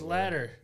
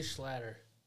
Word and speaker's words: Schlatter: How do you say that last name? You're better at pronouncing last names. – Where am I looking? – Right there. – Oh. Chalet. Schlatter. – Yeah. – Charlie Schlatter.